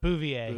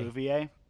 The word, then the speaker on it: Bouvier: It's also pretty forced, and like it was interesting how um, uh, uh, how how do you pronounce her last name? Uh, Bo- Bovier. Is that it? Uh, Bouvier. – Bouvier.